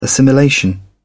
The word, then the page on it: assimilation: British English